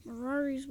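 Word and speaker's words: Mirari's Wake: Miri's Wake does... – Mirari's Wake. I keep saying it wrong.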